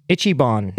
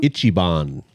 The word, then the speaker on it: Ichiban: Ichiban. – Ichiban? – Ichiban.